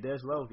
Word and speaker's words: Dej Loaf: Dej Loaf. – Oh yeah, Dej Loaf, yeah.